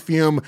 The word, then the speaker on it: Fume: All right, let's take a quick moment and thank one of our brand new sponsors on the show, which is Fume, spelled F-U-M, pronounced Fume.